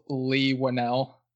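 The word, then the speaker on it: Leigh Whannell: There's this YouTube video with clips of different interviews where they pronounce it as Lee Wannell.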